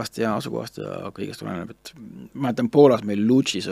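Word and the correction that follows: Łódź: õigemini Vutšis tähendab, hääldatakse, oli äh, tohutu suur klubi, suurem kui Rock Cafe mm.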